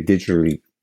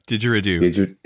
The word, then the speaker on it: didgeridoo: Now, if someone's playing a didgeridoo, I can't even pronounce it, so help me out on that. – Didgeridoo. – Didgeridoo.